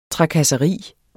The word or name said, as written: trakasseri